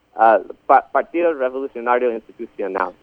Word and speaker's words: Partido Revolucionario Institucional: Partido Revolucionario Institucional, which is the Institutional Revolutionary Party, right, as you're saying.